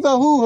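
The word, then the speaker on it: Vahu (והו): And then Rabbi Yehuda says, Ani Vahu Hoshiana. Ani Vahu Hoshiana. Vahu is spelled Vav He Vav, not Hu like He Vav Aleph, but He Vav.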